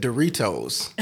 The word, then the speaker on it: Doritos: One day we were driving and she pronounced the T in Doritos. She said Doritos.